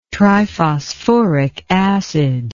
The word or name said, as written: triphosphoric acid